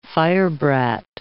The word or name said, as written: firebrat